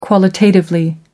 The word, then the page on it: qualitatively: American English